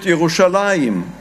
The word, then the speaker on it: Yerushalayim: Whereas in Hebrew, we call it Yerushalayim.